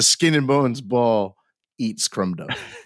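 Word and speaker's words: Crom Dubh: I mean, it's cool. It's C-R-O-M-D-U-B-H. – Okay. – Like Crumb Dove. – Yeah. – But it's pronounced Crumb Dove. – And it's the other name. One was Dire, the other Dire, yeah. D-A-I-R-E.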